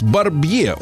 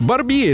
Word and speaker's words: Барбье: А в 1913 году родился подлец, мерзавец, э, маньяк Клаус Барбье. Он пишется, его фамилия пишется как Барби, поэтому э, и Е на конце там, да, после Барбие. Э, то есть некоторые неправильно читают. Барбье.